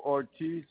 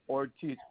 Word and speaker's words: Ortiz: Reynaldo Ortiz. It's spelled R-E-I-N-A-L-D-O, Ortiz, O-R-T-I-Z.